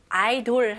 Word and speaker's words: idle: idol 할 때 idol 발음 똑같아요. 스펠링은 다르지만 IDLE.